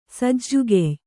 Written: ಸಜ್ಜುಗೆಯ್

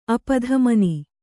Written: ಅಪಧಮನಿ

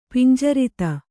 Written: ಪಿಂಜರಿತ

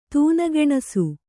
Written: ತೂನಗೆಣಸು

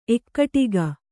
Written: ಏಕಟಾಕಿ

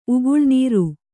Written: ಉಗುಳುನೀರು

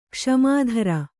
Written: ಕ್ಷಮಿತೃ

